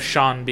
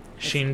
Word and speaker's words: Sean: If I stub my toe or hit my foot anywhere, I like to blame it on the fact that Sean Bean's name doesn't rhyme. – Sheen Bean? – It's not pronounced the way that it's spelled.